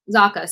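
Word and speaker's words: Zacha: Is it Zatra? – Zaka. – Sorry, I don't even know where I pulled an R from.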